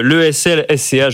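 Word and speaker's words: ESLSCA: Vous êtes président de l'AFAT, l'Association française de l'analyse technique, directeur du programme de finance de marché de l'ESL-SCA. Je ne sais pas si je le prononce. – L'ESL-SCA. – Donc je ne le prononçais pas bien.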